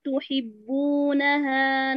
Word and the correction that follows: تحبونها: انتبهي هنا، بارك الله فيك، لحرف الهاء بالتحديد، أما تقولي تحبونها هكذا الهاء اختفت، وكذا لما قلتي من الله من الله، انتبهي لا تنطقي المخرج الحركة وتغفلي عن مخرج الحرف حتى لا يختفي معك، اثبتي على المخرج، تفضلي.